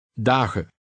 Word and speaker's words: dagen: Dagen. – The EN ending, which occurs frequently in Dutch, is always unstressed. And in conversational Dutch, the N in that ending is normally dropped.